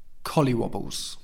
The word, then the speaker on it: collywobbles: Let's look at the pronunciation. Colly, colly, wa, wa, balls, balls. Collywobbles. Before going up to do a speech in front of the whole school, I got the collywobbles.